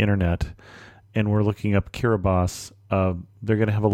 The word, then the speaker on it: Kiribati: So when you see T-I, that is S. So the country that looks like Kiribati is Kiribati. The capital area of Tarawa is spelled B-E-T-I-O, but it's pronounced Beso.